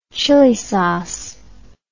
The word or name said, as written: chili sauce